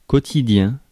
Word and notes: quotidien: France; [kɔ.ti.djɛ̃]

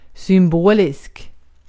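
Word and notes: symbolisk: Swedish

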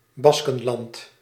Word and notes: Baskenland: /ˈbɑskənˌlɑnd/

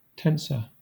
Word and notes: tensor: /ˈtɛn.sə/; /ˈtɛn.sɔː/; /ˈtɛn.sɚ/; /ˈtɛn.sɔɹ/

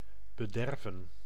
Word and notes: bederven: /bə.ˈdɛr.və(n)/

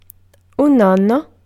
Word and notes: grandfather: American English; [ˈgɻændˌfɑðɚ]

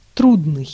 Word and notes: трудный: /ˈtrudnɨj/

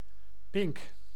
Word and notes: pink: [pɪnk]

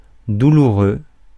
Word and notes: douloureux: /du.lu.ʁø/; France

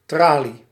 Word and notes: barreau: [ba.ʁo]; France

